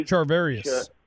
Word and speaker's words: Charvarius: Charvarius.